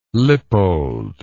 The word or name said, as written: lippold